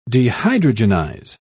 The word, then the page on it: dehydrogenize: British English